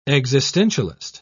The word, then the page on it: existentialist: British English